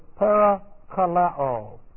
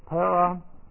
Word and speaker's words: parakaleo: The root word is parakaleo. Parakaleo. And this is P-A-R-A, and that's a prepositional prefix, K-A-L-E, long O, parakaleo.